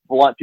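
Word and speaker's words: Blount: So I say blunt, people would typically spell it B-L-U-N-T, although it's B-L-O-U-N-T.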